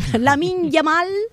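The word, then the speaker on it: Lamine Yamal: Padres y los hermanos, porque el hermanito de Lamil, ¿lo dije bien, Andrea? Lamin Yamal, el hermanito, vamos, se ha convertido en un icono para la selección española.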